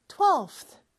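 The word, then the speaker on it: twelfth: 근데 12의 V 발음이 TH를 붙였을 때는 좀 발음하기가 어려워서 비슷한 F 발음으로 바꿔서 12th 이렇게 발음한다고 했죠. 요것도 사실 어렵기는 해요. 왜냐하면 우리말에는 없는 바람 소리거든요. 자, F 발음은 윗니 아랫입술이 된 상태에서 P라고 하는 것처럼 그냥 바람만 내뿜어 보세요. 그러면서 혀를 윗니와 아랫니 사이에 껴나면서 쓰라고 하는 것처럼 또 발음을 내뿜어요. 두 개를 이으면 요 소리예요. 네, 이게 원어민 귀에는 잘 들려요. 여러분들도 많이 연습하면 그게 자연스럽게 될 거예요. 12th, 12th 이렇게 빨리 발음해 주면 돼요.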